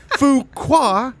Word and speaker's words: Fuqua: Fuqua is the director of Training Day,